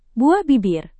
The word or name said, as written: buah bibir